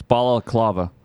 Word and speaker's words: Balaclava: It's Balaclava. – Not Bel E. – No. – Cleavy? – No. – Cleave? Bella Cleave? No, I guess I've been saying it wrong.